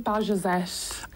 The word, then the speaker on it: Bajazet: I'm calling it Bajazet. – Are you? – Bajazet would be like the French yes. – Pronunciation of it.